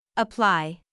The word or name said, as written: apply